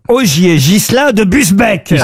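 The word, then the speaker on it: Busbecq: Ogier Gillin de Bubesque. – C'est vous dire que Benjamin Amran, qui habite Villeneuve d'Ornon, a une chance en Gironde de toucher un chèque RTL. Ogier Gillin de Busbeck, pardon, j'ai écorché son nom.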